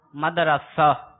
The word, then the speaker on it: مدرسہ: مدرسہ. را کے اوپر فتح. – را کے اوپر فتح ہے، را کے اوپر آپ کہہ رہے ہیں زبر.